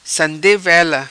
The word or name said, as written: Sande Vela